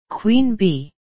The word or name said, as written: queen bee